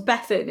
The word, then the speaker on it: Bethan: I feel like her name was Bethan. It wasn't Beth Ann. – Yeah. So apparently in Canada, it's Bethan. – Bethan. And in Danish.